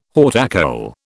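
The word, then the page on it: haute école: British English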